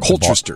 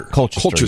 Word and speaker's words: Colchester: So legend has it that a Connecticut town of Colchester, I think that's how you pronounce it. I always get Colchester. – Colchester. Yeah, I know.